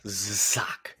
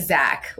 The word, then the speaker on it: Zsak: I had to look up how to pronounce this name because it's Hungarian and it's spelled Z-S-A-K. – Zsak. – So it's Zsak. Yeah. Not like Zach, like an American Zach would be.